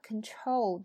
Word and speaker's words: control: 那么变成了 control 的一部分以后，它就不是 con 了。所以很多同学读错也会读成 control，这也是错误的，应该是。 Control，重音在 chol 上，前面是 can， right？ Control。